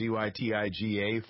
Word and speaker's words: Zytiga: Notice this week that there's a approval by, I guess, what, the FDA, this drug, Zytiga, is that how you pronounce it, Zytiga, for prostate cancer, advanced cases of it? – Yes, this is some hope, and it just shows how hard it is to treat advanced cancer.